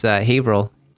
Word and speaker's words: Haverhill: Kudos to you for pronouncing it properly. Most people would say Haverhill, but it's Haverhill. Oh, yeah. No, I learned quickly it's Haverhill.